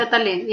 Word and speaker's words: Catalin: And your mother returns home with Catalin. Have I pronounced his name right? – Catalin, yes.